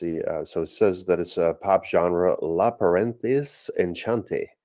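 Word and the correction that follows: enchantée: Obviously, I'm saying that wrong because I'm assuming that's French. Is it French? – Enchanté, yeah. Enchanté. – Enchanté, there we go.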